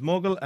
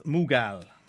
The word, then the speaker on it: Mughal: It's can be pronounced two ways. One is Mughal and one is Mughal.